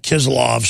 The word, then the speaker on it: Kislyov: Television presenter Dmitry, pronounce his name right, Kislyov, said on his weekly current affairs show, that guy was putting power over that channel by Vladimir Putin.